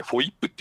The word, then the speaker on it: FOIP: FOIP と書いて、フォイップって読むんですけど、はいはい、これをね、まあ、考えたのはチーム安倍なんですよね。